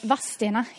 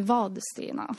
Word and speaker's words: Vadstena: Vadstena heter det ju då för oss som är lokalbor så att säga. Inte Vadstena.